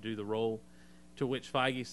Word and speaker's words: Feige: He and Kevin, I've heard his name pronounced Feige. So that's what I'm going to go with. He and Kevin, I'd say Fergie.